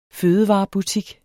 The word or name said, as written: fødevarebutik